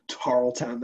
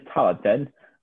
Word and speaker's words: Tarleton: Tarleton, that's the best name. Tarleton. It's like the classic 80s, 1880s, that is, Tarleton.